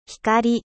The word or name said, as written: lumiere